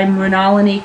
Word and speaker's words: Mrinalini: The March 23rd program is The Sum of All Parts, which is a hilarious comic piece by Mrinalini Kamath. I've been drilled on how to say her name. – Mm-hmm. – Rhymes with colony.